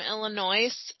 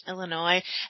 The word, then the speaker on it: Illinois: So the very first time I ever met somebody from Illinois, I said, oh, you're from Illinois. And he was a real dick about it. And he was like, it's pronounced Illinois.